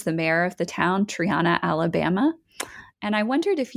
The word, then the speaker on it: Triana: It took me a while to learn how to say it, Triana, Alabama. And yeah, and I came across Triana's quote unquote DDT problem.